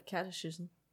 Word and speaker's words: catechism: I have the pronunciation of catechism. – Catechism. – Okay, that's what I thought it was. And then you put a ch in it and I was confused.